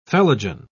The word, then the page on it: phellogen: British English